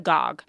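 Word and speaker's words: Gág: While her last name is spelled G-A-G, it's pronounced as Bog, not Bag. Yes, I'm talking about Newbery Award-winning author Wanda Gogg.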